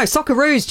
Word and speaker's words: Socceroos: No, Socceroos, Jane.